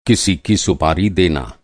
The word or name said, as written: सुपारी